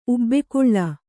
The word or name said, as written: ಉಬ್ಬೆಕುಳ್ಳ